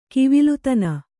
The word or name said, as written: ಗಾವಿಲತನ